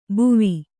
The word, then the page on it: ಬುವಿ: kannada